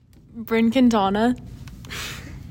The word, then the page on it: Brynn Quintana: BRIN KEEN tah nah